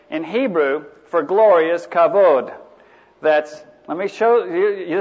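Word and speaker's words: kavod (כבוד): That's a K, that's an A. So that's ka, and this here is pronounced like a V, so that's kav, that's an O, and that's a D, so it's kavod.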